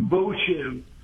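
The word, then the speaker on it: Voche: V as in Victor, O-C-H-E with an accent. Acute accent on the top. – Yep. – Boche.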